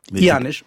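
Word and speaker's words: Eanes: c'est le temps du président Janus. – Janus. Mmh. – Ah, vous prononcez maintenant très très bien le portugais.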